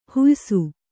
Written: ಹುಯ್ಸು

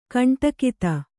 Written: ಕಂಠೋಕ್ತ

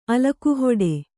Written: ಅಲಕುಹೊಡೆ

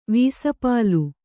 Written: ವೀಸಪಾಲು

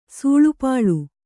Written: ಸೂಳುಪಾಳು

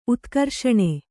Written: ಉತ್ಕರ್ಷಣೆ

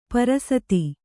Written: ಪರಸತಿ

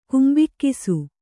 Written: ಕುಂಬಿಕ್ಕಿಸು

